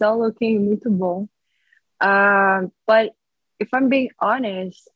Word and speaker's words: muito bom: It's all looking, how you'd say, muito bom right now for your country. – I was wondering how you're going to pronounce that. Really good.